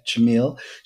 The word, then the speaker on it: Chmiel: Chmiel, with a hard ch. – Chmiel. Uh-huh. All right, Dr. Lina Chmiel.